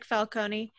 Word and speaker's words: Falcone: No, I like Falcone. – Either way is fine. – I'm Italian. That's how you're supposed to pronounce it.